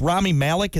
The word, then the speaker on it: Rami Malek: Then the release of Bohemian Rhapsody, which took the young actor of, it's Rami Malek. Is that how you say his name? Mm-hmm.